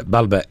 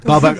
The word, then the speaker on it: Baalbek: Yeah, he said it correctly. – Yeah, Baalbek. – Baalbek.